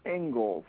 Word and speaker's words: Inglis: The second function of schooling, according to this guy's name was Alexander Ingalls, although it's spelled I-N-G-L-I-S,